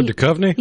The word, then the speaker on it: Duchovny: Oh, Duchovny? – Yes.